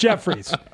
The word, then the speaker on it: Jeffries: It's pronounced Jeffries, but it's always the joke that it was should be pronounced Jeff Fires.